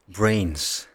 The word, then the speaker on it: Brains: Brains. Brains. Zo spreek je het uit. – Ja, het is het. – Denk ik. Ja, ik, hier staat ook fonetisch brains. Ah ja.